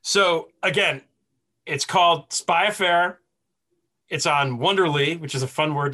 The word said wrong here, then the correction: Wondery: That's why it's fun to say, because I'm saying it wrong. It's Wondery, which is really Wonder Why, right?